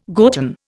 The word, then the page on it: gotten: Indian